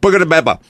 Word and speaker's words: Buca di Beppo: Buca di Beppo.